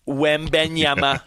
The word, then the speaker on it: Wembanyama: And yeah, I know Wembanyama is a work in progress. He's not even able to drink alcohol. He's a, you know, 7'5", 19-year-old kid. – How do you say it? Wembanyama.